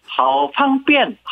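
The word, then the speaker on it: 하오팡뺀 (好方便): 중국어로 하면은 삼성, 일성, 사성입니다. 그래서 하오팡뺀. 아주 편리합니다. 하오라는 말이 아주 매우라는 얘기죠. 하오팡뺀이었습니다. – 중국 소식을 아주 편리하게 듣고 있습니다. 하오팡뺀.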